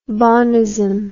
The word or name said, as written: bonism